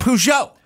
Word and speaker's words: Peugeot: It's Peugeot. But you I don't go Pujago.